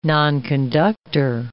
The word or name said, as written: nonconductor